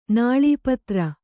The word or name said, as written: ನಾಳೀಪತ್ರ